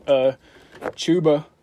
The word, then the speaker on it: Chuba: Chuba, I'm going to say his name right finally.